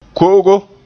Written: ko'ogo

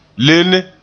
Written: lenne

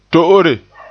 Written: to'ore

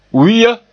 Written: wi'a

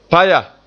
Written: paya

